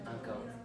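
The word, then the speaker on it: ankaŭ: Sello acao estas membro. – ¿Qué error encontramos en. Un solo error en la pronunciación, en el acento? – Ancao. – Ancao. Él mismo, mira, se dio cuenta, él mismo.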